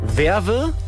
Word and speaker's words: Vevais: Vorsicht bitte auf der B167 in WWS. Nee, jetzt habe ich es doch falsch ausgesprochen. Werve, WWS, also ich buchstabiere es Ihnen mal. V-E-V-A-I-S.